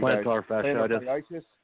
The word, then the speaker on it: plantar fasciitis: Uh, Amari Cooper uh, popped up with a uh, with a foot injury, and he already had an existing plantar uh, how, how you pronounce this? Forgive me, plantar fasciitis.